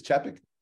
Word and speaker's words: Chapek: Uh, I believe it's Chapek.